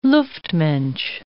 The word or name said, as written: luftmensch